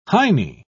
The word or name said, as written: heinie